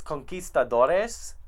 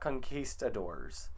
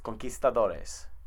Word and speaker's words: conquistadores: It's conquistadores, not conquistadors. Okay, just to throw that out there, it's conquistadores.